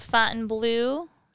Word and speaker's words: Fontainebleau: We're going to talk about Fontainebleau State Park.